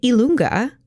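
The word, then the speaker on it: i lunga: And Ilunga is how you say the letter J in Italian.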